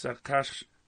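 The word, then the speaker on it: Achashverosh: It's Akashvarash. I'm saying it right.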